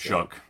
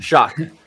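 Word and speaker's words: Shough: I can't pronounce this guy's Shuck. – Name. Shuck. That's crazy. That's Shuck.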